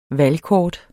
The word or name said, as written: valgkort